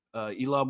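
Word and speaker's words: Eelam: the Tamil anti-government insurgent force, declared the first Elam, is that how you pronounce it? Elam War? – Yep. Perfect, yep.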